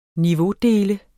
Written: niveaudele

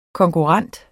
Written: konkurrent

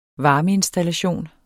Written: varmeinstallation

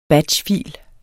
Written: batchfil

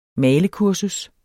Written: malekursus

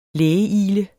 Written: lægeigle

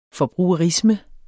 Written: forbrugerisme

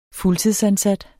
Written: fuldtidsansat

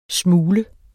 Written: smule

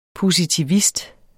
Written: positivist

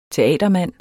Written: teatermand

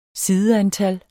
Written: sideantal

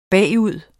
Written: bagud

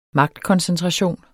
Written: magtkoncentration

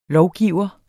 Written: lovgiver

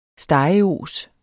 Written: stegeos

